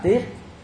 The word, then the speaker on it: Tiết: Trong chữ Hán á, Tết đọc đúng âm là Tiết.